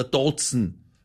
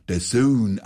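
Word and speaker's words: DAZN: Natürlich, man braucht kein Sky oder Dotzen. – Allmächt, heute wieder lauter Profis am Start. Das eine heißt nicht Sky, sondern Sky und das andere spricht man The Zone aus.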